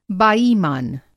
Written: बाईमान

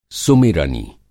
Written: सुमिरनी

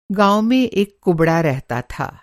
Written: कुबड़ा

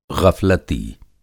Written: गफ़लती

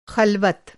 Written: ख़ल्वत

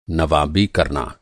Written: नवाबी

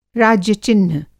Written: राज्य-चिह्न

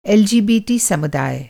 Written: एलजीबीटी

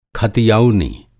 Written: खतियौनी

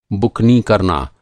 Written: बुकनी

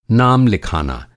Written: नाम

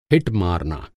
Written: हिट

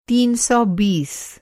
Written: तीन सौ बीस

